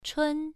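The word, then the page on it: chun: tone 1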